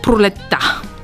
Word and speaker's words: пролетта: Пролетта, гордостта, младостта. Забелязваме, че ударението пада на члена та. Съответно, този звук А е А. Той е чистия звук А. И ние не трябва да го произнасяме.